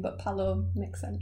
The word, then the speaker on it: Pallot: A G, then a B, and then Pallot P A double L O T but I think oh. I'm going to pronounce it Palo. Yeah, yeah, Palo.